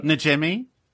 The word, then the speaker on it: Najimy: I would keep, how do you say Kathy's last name? – Najimy? – Yeah, I would bring her back, yeah.